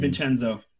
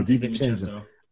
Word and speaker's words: DiVincenzo: Because I think I read something and they said Dante DaVinci, DaVinci? I don't know how to say it. – DiVincenzo. – Yeah, DiVincenzo.